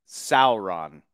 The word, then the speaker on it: Sauron: But it Sauron, that's how you pronounce it. It's not like a dinosaur.